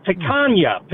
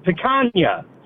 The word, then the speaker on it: Picanha: You're totally pronouncing it wrong there, Nick. It's Picanha. Picanha. Say it with me.